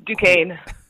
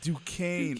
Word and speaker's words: Duquesne: So obviously you chose, and now don't make fun of me, but is it Duquesne? – Don't worry. It's Duquesne. – Damn! Duquesne! I even pronounced the S, too? – Oh, no. – Oh, yeah, the S is silent.